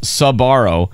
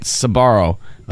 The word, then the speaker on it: Sbarro: The family's last name is S B A R R O, pronounced Sabaro. Uh-huh. Some people elongate the sa and make it Sabaro, but uh-huh. no, the sa gets truncated by the bar. It might look sound like Sparrow.